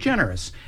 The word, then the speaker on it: DeGeneres: Ellen DeGeneres, is that how you pronounce DeGeneres. – It? DeGeneres.